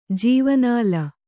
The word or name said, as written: ಜೀವನಾಲ